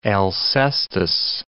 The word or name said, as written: Alcestis